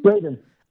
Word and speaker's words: Raiden: Raiden.